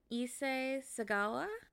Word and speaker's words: Issei Sagawa: Anyways, I'm going to be talking about Issei Sagawa. Is that how you would have said it? – Yeah.